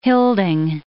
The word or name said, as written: hilding